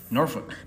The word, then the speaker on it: Norfolk: Norfolk. – Norfolk. Because I keep saying Norfolk, and I'm like, that's not right.